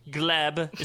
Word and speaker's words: Gleb: Erika Jayne partnered with Gleb. Is that how you say his name? – Yup.